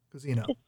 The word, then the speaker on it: Cuzino: Cuzino? – Cuzino. – Cuzino. – Yeah. – Regular old Cuzino, just like it looks.